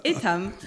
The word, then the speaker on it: Etam: So you are pronouncing it in the right way, actually. – I made the effort. – The French way, it's Etam.